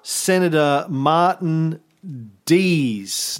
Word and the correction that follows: Dies: No, Congressman Martin Dees. – Dies. – Dies. Junior. You told me it's pronounced Dies.